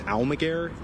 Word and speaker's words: Almaguer: Miguel, just for the record, can you Yes, my first name is spelled M-I-G-U-E-L. My last name is Almaguer, A-L-M-A-G-U-E-R.